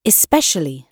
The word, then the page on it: especially: British English